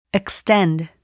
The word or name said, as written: extend